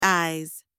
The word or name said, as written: eyes